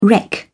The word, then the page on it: wreck: British English